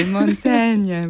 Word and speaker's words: Montaigne: The French writer Montaigne. – Very good. Montaigne, very good.